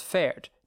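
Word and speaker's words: Fahrt: So like if an English speaker pronounced fared as fared, that's fine. That's the English version of that word.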